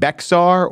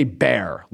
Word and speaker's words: Bexar: So I knew that it wasn't Bexar or Beeksar, but it was Bear. The thing is, they just say Bear, like Ursine, Bear. I tried to give it an extra syllable. It seems that B-E-X-A-R demands an extra syllable. They're having none of it.